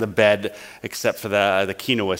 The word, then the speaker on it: quinoa: Then we have some baked Dijon mustard chicken breast on a bed of quinoa seeds. I was saying quinoa last night. It's two syllables, right? Quinoa.